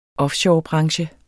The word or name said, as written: offshorebranche